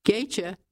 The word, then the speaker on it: Gaethje: Gaethje, and by the way, I hope I'm pronouncing that correctly. It is spelled G-A-E-T-H-J-E, and I'm pronouncing it Gaethje.